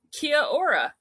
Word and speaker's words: kia ora: Well, kia ora.